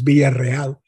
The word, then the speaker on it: Villarreal: Hector's name, that's one of his surnames, is Villarreal. He spells it with one R. Well, it's spelled with two R's. Because in Spanish, an initial R is pronounced as a double R.